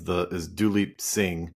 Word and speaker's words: Duleep Singh: So one of the most interesting figures in your book is Duleep Singh.